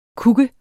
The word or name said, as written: kuk